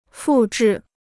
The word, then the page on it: 复制: fù zhì